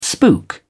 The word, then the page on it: spook: British English